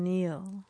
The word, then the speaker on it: kneel: How are you spelling Neil? – K-N-E-E-L, Neil.